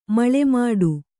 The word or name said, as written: ಮಳೆಮೋಡ